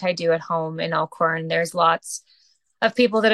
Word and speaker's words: Elkhorn: Talk to me about your hometown of is it I don't want to pronounce it badly, but is it Elkhorn, Manitoba? – That's correct. Yeah, Elkhorn.